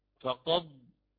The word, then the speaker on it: فقد ضل: الدال إن كانت ساكنة فكما ترون حتى في المصحف تجعل بلون رمادي حتى تبين أنها لا ينطق بها، بل تدغم في الضاد، في الضاد فقط. فنقرأ هنا فقض إدغام كامل.